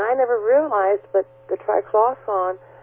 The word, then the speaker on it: triclosan: The other thing I wanted to add was a follow-up from when I was talking about the antibacterial product that contains, I think the way you pronounce it is triclosan, T-R-I-C-L-O-S-A-N.